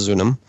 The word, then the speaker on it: Zunum: And it's spelled several different ways, but it's pronounced Zunum.